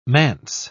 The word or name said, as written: manse